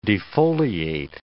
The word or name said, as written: defoliate